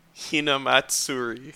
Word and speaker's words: Hinamatsuri: Hinamatsuri.